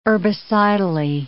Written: herbicidally

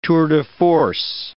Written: tour de force

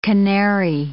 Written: canary

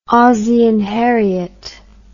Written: Ozzie and Harriet

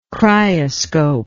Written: cryoscope

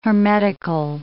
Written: hermetical

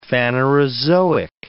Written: Phanerozoic

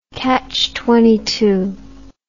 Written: catchtitle

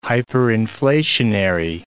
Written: hyperinflationary

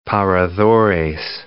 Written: paradores

